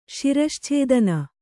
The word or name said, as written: ಶಿರಶ್ಛೇದನ